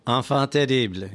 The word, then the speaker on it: enfant terrible: Enfant terrible, yes.